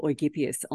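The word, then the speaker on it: Eugyppius: For anyone who follows Eugippius, or the German pronunciation would be Eugippius on Substack, he's a German academic who writes just fabulous pieces.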